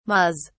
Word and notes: mas: British English

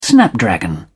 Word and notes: snapdragon: British English

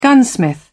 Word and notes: gunsmith: British English

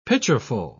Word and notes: pitcherful: British English

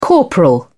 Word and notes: corporal: British English

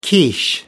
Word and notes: quiche: American English

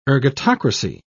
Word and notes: ergatocracy: British English

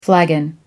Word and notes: flagon: American English

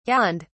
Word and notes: yawned: British English

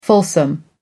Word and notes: fulsome: American English

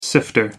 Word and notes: sifter: American English

